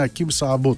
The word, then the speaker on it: Ακίμ Σαμπό: Έτσι, το έτσι δεν προφέρεται, το πω ακόμη μια φορά, το έχει πει το ίδιο το παιδί, είναι Ακίμ Σαμπό το όνομά του. Αν κάποιοι απορείτε γιατί τον προφέρω κατά αυτόν τον τρόπο, γιατί το έχει πει έτσι ο ίδιος.